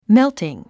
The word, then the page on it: melting: méltiŋ; メゥティン